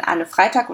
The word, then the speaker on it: Anne Freytag: Ich habe heute zwei ganz besondere Gäste bei mir, nämlich die Autorinnen Anne Freitag und Adriana Popescu.